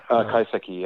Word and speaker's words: kaiseki: So, and again, forgive my amateur understanding of so much of this, but I do love the concept of kaiseki. Am I saying that right? I may not be saying that right. – Kaiseki, yeah. – Kaiseki.